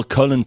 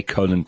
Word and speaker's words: Colin: In the news, first of all, Colin Powell is dead. I say Colin Powell, but we would pronounce it Colin Powell. We say colon when we're talking about our digestive tract.